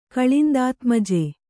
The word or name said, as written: ಕಳಿಂದಾತ್ಮಜೆ